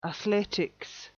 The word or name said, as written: athletics